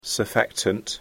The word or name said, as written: surfactant